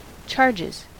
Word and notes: charges: American English

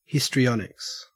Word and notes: histrionics: Australian English

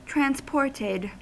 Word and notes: transported: American English

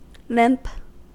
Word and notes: limp: American English

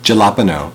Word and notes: jalapeno: American English

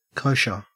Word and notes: kosher: Australian English

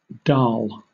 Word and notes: dal: British English

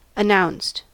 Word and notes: announced: American English

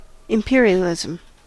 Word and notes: imperialism: American English

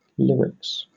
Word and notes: lyrics: British English

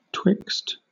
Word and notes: twixt: British English

